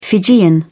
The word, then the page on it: fijian: British English